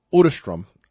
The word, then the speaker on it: Otterstrom: I think it's actually pronounced Otterstrom. I got it right during his interview. I know that.